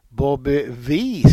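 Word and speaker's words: Bobby Vee: Jag uttalar det här efternamnet då fel. Bobby Wee ska det ta uttalas. – Jajamän. – Inte Wee som jag sa.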